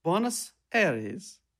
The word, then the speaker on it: Buenos Aires: Buenos Aires. – Yes, it is. Can we get a pronunciation check there? – Buenos Aires.